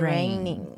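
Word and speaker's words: reign: 真的，我觉得真的还蛮的，因为她统治了 70年嘛，统治就是 reigning。 – Rain 那个发音跟下雨一模一样、嗯、只是拼字不一样，是 R-E-I-G-N、嗯、不是 R-A-I-N。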